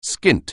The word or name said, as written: skint